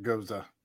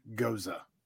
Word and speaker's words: Gose: Some people pronounce it Goza. That word, G-O-S-E.